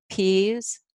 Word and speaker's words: Pease: It's easy to find Wendy Pease, and that's spelled P-E-A-S-E at Rapport International.